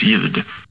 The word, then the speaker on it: ljud: Men sen kanske försöka ta bort de här bokstäverna som inte uttalas alls i ljud, eller i ord, som exempelvis ljud.